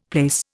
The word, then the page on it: plaice: Indian